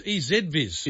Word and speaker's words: EZVIZ: This is EasyViz. – EasyViz. Yeah, okay. I should have put a pronunciation. – Hang on, it can't be. It's EZViz. – It's EZ. – We say Z.